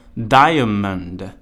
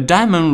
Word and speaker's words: diamond: 呃，有的时候呢，有一些会配有发音的字典也会读作 diamond，但实际上在生活当中，呃，native speaker 一般不会把那个 diamond 里面的 a 读出来，一般会念作 diamond，diamond，a diamond ring，一枚钻戒，ring，r i n g，就是戒指的意思。